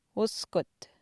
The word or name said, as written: uskut